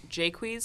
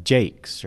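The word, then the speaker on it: Jaques: And the character's name is actually pronounced Jacques. – Wait, Jake? Really? Because it sure looks like Jacques.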